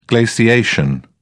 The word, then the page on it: glaciation: British English